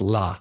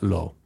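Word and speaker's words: la (לא): It's la, not is la, not lo.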